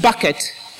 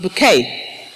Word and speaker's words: Bucket: Or are we going to be like Mrs. Bucket, pronounced bouquet, and be stuck in a relentless pursuit of a seat at the top table?